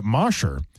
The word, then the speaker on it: Mosher: But Mosher, because mosh pit, M-O-S-H-E-R.